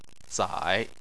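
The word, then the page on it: zai: tone 3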